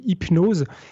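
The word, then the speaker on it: Hypno5e: alors ça se dit Hypnose, mais ça s'écrit Hypno 5. E.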